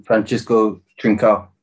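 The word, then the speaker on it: Francisco Trincão: Francisco Trincao.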